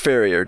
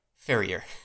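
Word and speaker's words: farrier: And it's pronounced Ferrier, just so you know. – Ferrier.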